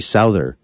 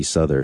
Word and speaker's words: Souther: And I said that wrong. It's J.D. Souther, not J.D. Souther.